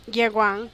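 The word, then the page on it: yeguang: Teotitlán del Valle Zapotec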